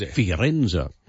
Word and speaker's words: Firenze: Because it's Firenze.